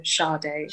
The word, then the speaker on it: Sadé: So it's like, you know, the singer Sade. It's like Sade. – Oh my God. – That's how you pronounce it.